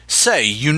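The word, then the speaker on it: Ceiu: Usually it's spelled C-E-I-U, I believe, pronounced say-you.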